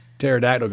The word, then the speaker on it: pterodactyl: No, it's how come you couldn't hear the pterodactyl go to the bathroom? – Why not? – Because the P is silent.